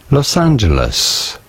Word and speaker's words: Los Angeles: In English, or in American English, it is usually pronounced as Los Angeles.